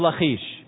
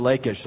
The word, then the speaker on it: Lachish: I know some of you pronounce this Lachish. Mark Finley and I did a whole program there for It Is Written several years ago, 10 years, 11 years ago now. And we went back and forth while we were in the field whether we were going to say Lachish or Lachish. And I can't remember what we decided. But anyway, they're both correct. I just used the more Hebrew pronunciation.